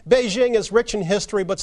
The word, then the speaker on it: Beijing: That correct pronunciation is Beijing. And I think it's quite simple to get it pretty close. And I feel like the jing, as in jingle bells, is something that all English speakers should be able to manage.